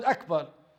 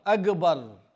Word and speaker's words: Akbar: It's like the G, how they pronounce garden. They say Akbar. That's invalid as well. So it's invalid as well to say it in this way. So one needs to learn Akbar, Allahu Akbar. Not Akbar, not Akbar.